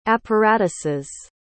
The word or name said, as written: apparatuses